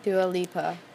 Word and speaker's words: Dua Lipa: By Dua Lipa, I think. I don't know how you say it. – Dua Lipa.